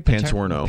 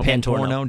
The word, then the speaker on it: Pantorno: the first, like, 5 minutes of the show, Kevin and Joe Paterno. Did I pronounce that right? – Pantorno. – Pantorno. Joe Pantorno were talking about the NHL.